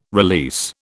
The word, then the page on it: release: British English